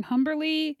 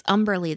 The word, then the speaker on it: Humberly: Sunday, December 17th, the last Hallmark movie is A Friends and Family Christmas, starring Humberly Gonzalez and Allie Leibert. – Oh, it's Umberly, the H is silent.